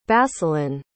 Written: basilan

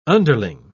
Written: underling

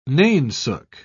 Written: nainsook

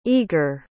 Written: eger

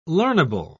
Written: learnable

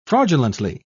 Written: fraudulently

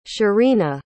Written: shirina

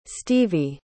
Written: stevie

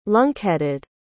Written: lunkheaded

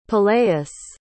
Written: peleus

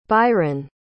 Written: Byron

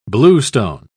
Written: bluestone

